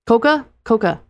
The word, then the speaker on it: coca: While Coke executives were indeed relieved the new formula contained no cocoa. Coca. – You're asking me? – It's spelled C-O-C-A. It's spelled Coca. Coca.